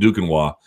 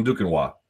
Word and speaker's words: Duquesnoy: If you guys don't know Tom Duquenois, and for my American friends who couldn't possibly spell that without assistance, D-U-Q-U-E-S-N-O-Y. Remember those, like, Canadian goalkeepers in hockey? Goalies? Roy, but they're pronounced Wah. Same thing. Tom Duquenois.